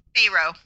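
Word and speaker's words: Pharoah: American Faroa? – Pharaoh. – Oh, it's Pharaoh?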